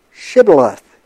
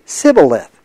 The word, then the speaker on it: Shibboleth: If he said no, then they would say to him, then say Shibboleth. And he would say Sibboleth, for he could not pronounce it right.